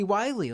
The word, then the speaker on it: Wylie: I just thought he was Willie spelt with a Y, but we were talking prior to this. And you informed me that his name was actually Wiley, like Wiley Coyote.